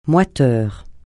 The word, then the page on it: moiteur: francais